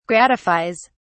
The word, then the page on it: gratifies: British English